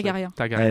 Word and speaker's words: Targaryen: Dernerian, j'arrive pas à le prononcer. – Targaryen.